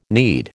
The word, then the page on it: knead: American English